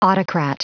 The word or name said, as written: autocrat